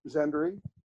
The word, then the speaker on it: Szendrey: It's pronounced the same way it's spelled, Szendrey.